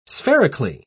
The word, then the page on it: spherically: British English